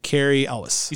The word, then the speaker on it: Cary Elwes: Played by Cary Elwes.